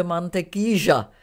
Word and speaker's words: mantequilla: já, já, jenom vím, já jenom vím, že je, eh, jsem se musela tam, třeba ve Španělsku, ty dvě L se vyslovují jako L a tam se to vyslovuje jako Ž. Třeba mantequilla, to je máslo, a tam se to vyslovuje mantequíža.